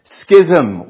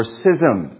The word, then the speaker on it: schism: Schism or schism. It's pronounced either way.